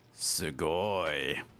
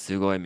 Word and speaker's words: sugoi: Sugoi. – And what does sugoi mean? – It's su-go-i. Sugoi. – Great pronunciation.